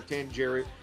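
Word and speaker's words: Tsangarides: But, this is a great album produced by Chris, I know I'm pronouncing this wrong, Tangeris? Yeah, okay, there we go, Tangeris.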